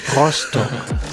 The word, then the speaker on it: Rostock: Now it's time for the Rostock quiz, which is locally pronounced Rostock.